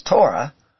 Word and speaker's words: Torah: In the Hebrew, we see this word that we pronounce as Torah, as the letters of Tav, Vav, Resh, He.